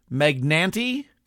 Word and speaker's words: Magnante: Now, last week on the show, I played Milwaukee, the Home of the Braves, by Charles Magnante. Do you pronounce the E on the end? M-A-G-N-A-N-T-E. Charles Magnante. Magnante sounds classier anyway.